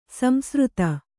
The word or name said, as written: ಸಂಸೃತ